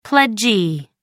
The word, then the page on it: pledgee: American English